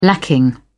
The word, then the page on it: lacking: British English